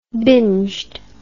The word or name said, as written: binged